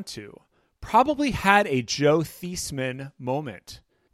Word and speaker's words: Theismann: And the guy from Notre Dame, as the story went, said, No, it's Theismann. It rhymes with Heisman.